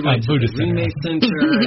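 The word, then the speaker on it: Rime Center: Cynthia and I went to, is it pronounced the Rhyme Center? – Rhyme Center.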